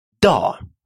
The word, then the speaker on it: dag: «Da» – день. «Da». Кстати, обратите внимание, что последнее «г» в этом слове не читается.